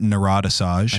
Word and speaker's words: Narodasaj: It's like, I always pronounce it Narodasaj. – That's right. Okay. – Yeah, that's how I pronounce it, but it's Narodasaj. It's like Joshua Dorn backwards. Completely.